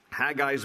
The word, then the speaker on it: Haggai: His name in Hebrew is spelled with a C-H, just as that huh sound, so it's Haggai. Haggai is his name.